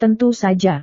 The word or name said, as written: tentu saja